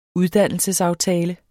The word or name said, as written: uddannelsesaftale